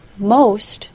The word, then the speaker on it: most: Most, M-O-S-T, and cost, C-O-S-T, don't rhyme. The letter O in most is a long O sound, O, and the letter O in cost is an A-W sound, aw.